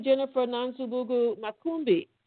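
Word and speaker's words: Jennifer Nansubuga Makumbi: Good morning, Jennifer Nansubugu Makumbi.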